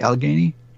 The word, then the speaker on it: Allegheny: like in the Allegheny. Did I pronounce that correctly, Allegheny? – You did.